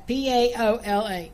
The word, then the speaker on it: Paola: Uh, but I did look it up, and it is P A. Like I was right, P A O L A. Paola.